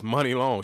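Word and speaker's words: Muni Long: It's Money Long.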